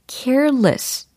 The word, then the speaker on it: careless: 아, C A R E L-E-S-S. 바로 이 철자이고, 발음을 careless라고 하시면 안 되고, careless. 이렇게. careless. careless. 두 번째 음절에 less 이렇게 발음이 처리가 되는 것을 좀 어, 귀담아 들으시면 도움이 될 거예요. less가 아니고 less 이렇게 약화가 됩니다.